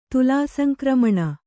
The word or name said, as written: ತುಲಾಸಂಕ್ರಮಣ